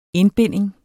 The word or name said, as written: INDBINDINGERNES